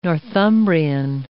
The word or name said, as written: Northumbrian